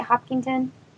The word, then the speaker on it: Hopkinton: grew up in Hopkinton with, am I saying that right? Hopkinton? – Yep. Mm-hmm. Okay.